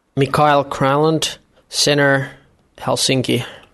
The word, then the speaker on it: Granlund: Mikhail Granlund, center, Helsinki. – Sounds like he's got it down to like one syllable.